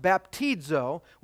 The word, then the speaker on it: baptizo: Baptism, it comes from a Greek verb pronounced baptizo, which means to dip under or immerse.